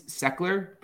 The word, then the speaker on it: Sechler: So my last name's Seckler, but it's like spelled like S-E-C-H-L-E-R.